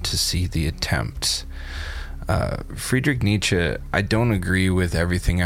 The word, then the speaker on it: Nietzsche: I feel that no batch of quotes from me would be complete if it didn't include something from my boy Friedrich Nietzsche. And yes, that is how you pronounce his last name.